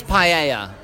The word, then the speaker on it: paella: So it's not paella, it is paella.